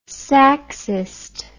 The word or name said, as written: saxist